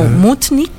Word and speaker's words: Munich: Él es Matías Munich. Yo lo pronuncio como en alemán, pero es como Mutnik.